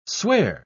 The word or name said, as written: swear